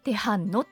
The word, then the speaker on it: 大学路: で「テハンノ」って読むんですね、